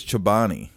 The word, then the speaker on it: Chobani: How do you say it? Is it Chobani? – I think it's Chobani. Chobani.